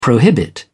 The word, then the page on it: prohibit: British English